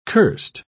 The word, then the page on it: curst: British English